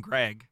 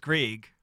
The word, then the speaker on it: Greig: Cause I've heard 90% of the hockey community call him Greg. The pronunciation guide says Greig.